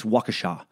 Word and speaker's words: Waukesha: Okay, first update, another tragic mispronunciation. The Slender Man stabbing took place in Waukesha, not Waukesha. It's Waukesha.